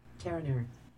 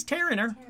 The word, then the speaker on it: Terenure: But let's see how you pronounce it in real life. Terenure. Oh, okay. It is Terenure. Terenure.